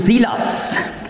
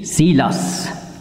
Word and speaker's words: Silas: Und griechisch ausgesprochen hat eben Shaul eben Saulus und Sheil Silas.